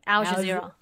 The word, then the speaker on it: Al Jazeera: Al Jazeera.